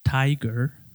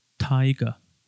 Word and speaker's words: tiger: Y el británico no pronuncia esa R. Y en vez de la R, escuchamos un sonido particular del inglés que se llama schwa. Escuchamos el schwa y tenemos el siguiente sonido. Taiga. Taiga.